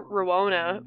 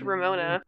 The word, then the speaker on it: Ramona: I want to call her Rowona, but it's Ramona.